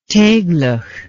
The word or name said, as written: teiglach